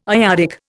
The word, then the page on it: iodic: Indian